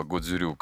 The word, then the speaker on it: Годзюрю: А, школа называется Окинава Годзюрю Каратэ-до.